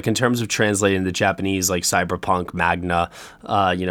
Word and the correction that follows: manga: know, series or manga, sorry, I said that wrong, manga series,